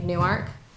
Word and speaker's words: Newark: And sadly, Alex Trebek mispronounced the name of Newark. He called it Newark. – And but then he corrected himself.